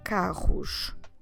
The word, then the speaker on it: carro: O carro os carros. Como se escreve carro? C A R R O carro.